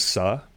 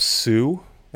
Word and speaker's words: Suh: The correct pronunciation is Suh. I grew up Sue, and I blame that on my parents. You know, it's more of an Americanized version of the last name.